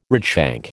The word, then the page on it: redshank: American English